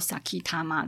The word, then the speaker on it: 前玉: 那这个前玉佐吉多万的念法，都念作 Sakitama 这样子。